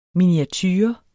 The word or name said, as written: miniature